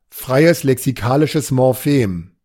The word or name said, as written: freies lexikalisches Morphem